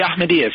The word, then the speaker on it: Ahmadiyyas: I might get the pronunciation wrong, but the Ahmadiyyas? – Yeah, the Ahmadiyyas, yeah.